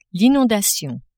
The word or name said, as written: inondation